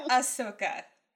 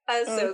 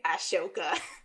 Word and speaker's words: Ahsoka: It's Ahsoka. – Ahsoka. Okay. But for the sake of this story, I'm going to say how she says it and say Ashoka.